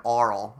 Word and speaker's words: aural: I think I overpronounce the AU. It. Yeah, the AU. Just so that it's to differentiate it from oral. Oral and aural.